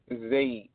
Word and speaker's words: Zaid: Zaid. Z-A-I-D.